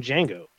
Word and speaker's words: Django: His son is named Django. – With a D? – With the D is silent.